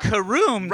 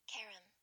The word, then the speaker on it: carom: Karam. So carom. – It's like Karen, but with an M at the end.